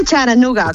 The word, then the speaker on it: Chattanooga: Se dice Charanooga, Charanooga, con la T como muy suave, sí.